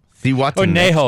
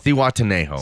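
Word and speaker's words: Zihuatanejo: Siwatanejo. – Or Nejo. – Siwatanejo.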